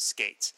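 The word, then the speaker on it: Skeates: and he confirmed that it's pronounced Skates.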